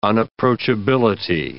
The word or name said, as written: unapproachability